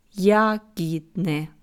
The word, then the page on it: Jahidne: slow